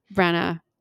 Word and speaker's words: Branagh: This is part of Kenneth Branagh's Branagh. – I think the G is silent.